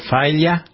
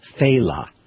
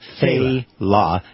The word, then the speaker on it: Failla: His name is pronounced Faila. Faila. It's spelled F-A-I-L-L-A. So people go, Faya, Faila. You know, but okay. It's Faila.